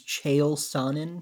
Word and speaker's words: Chael Sonnen: I think his name's Chael Sonnen. – Yes. – I'm sure I butchered that pronunciation. – No, I think you nailed it.